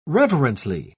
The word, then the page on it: reverently: British English